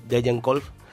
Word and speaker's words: Degenkolb: John Degenkolb, que este año ya lleva unas cuantas, pues ha hecho gala de eso, de que está en una forma magnífica.